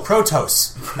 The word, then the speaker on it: Protoss: I'm embarrassed I just realized I've never heard Protoss pronounced. I was like, oh, Protoss.